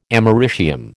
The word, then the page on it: americium: American English